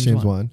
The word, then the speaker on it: James Wan: It's like I see so much where, you know, like the James Wan, James Wan. Wait, am I saying that right? Saw One. – That's James Wan.